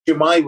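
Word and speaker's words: Shwmae: Shumai, yeah. – Shumai was correct. That is southern.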